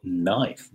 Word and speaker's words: knife: Por ejemplo, knife, no knife, pero knife desaparece, ¿no? No pronunciamos la K.